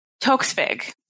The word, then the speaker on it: Toksvig: And then they brought in two new hosts, Noel Fielding and Sandy Toksvig, I believe. Toksvig. – Yeah, so I was trying to figure out how to say her name earlier, and I was like, Toksvig. Yeah, you've got it.